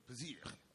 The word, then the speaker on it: Piszcz: P-I-S-Z-C-C. I didn't even spell it right. P-I-S-Z-C-Z. What is it? – Pisiczcz. – Okay, thank you.